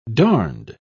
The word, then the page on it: darned: British English